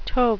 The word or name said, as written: tope